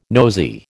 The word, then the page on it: nosey: American English